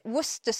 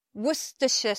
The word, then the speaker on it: Worcestershire: We call it Worcester sauce. Worcester sauce. Or some people say Worcestershire sauce. Two ways to pronounce that sauce.